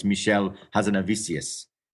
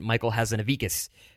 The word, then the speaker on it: Michel Hazanavicius: And of course, the fifth one is Michel Hazanavicius, if yes. – I'm saying that correctly. – You are. And actually, I got reprimanded. I pronounced it Michael Hazanavicius.